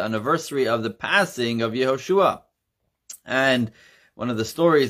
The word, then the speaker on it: Yehoshua: The proper pronunciation in Hebrew is Yehoshua.